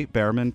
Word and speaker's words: Behrman: So it's at J Behrman, PhD. My last name is a bit tricky, but Bear is spelled like the paint, the brand of paint, B-E-H-R. And then man is MANN.